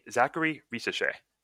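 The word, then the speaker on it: Zaccharie Risacher: All right, let's get into our next player, and that'll be Zachary Risache.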